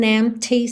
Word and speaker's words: empty: There's just an empty space. Empty. O P é mudo, tá? E o T é marcadinho. Empty. Empty space.